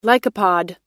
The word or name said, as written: lycopod